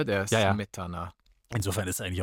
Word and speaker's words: Smetana: Der Name Smetana wird auf der ersten Silbe betont, Smetana. Wird er auf der zweiten betont, Smetana, dann bedeutet er im Russischen saurer Rahmen.